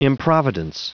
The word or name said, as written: improvidence